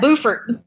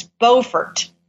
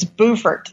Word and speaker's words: Beaufort: Beaufort, believe it or not. Now, somebody who is not from South Carolina tried to correct me. I was like, she said, it's not pronounced like that. I was like, well, in North Carolina, it's Beaufort.